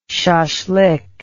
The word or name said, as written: shashlik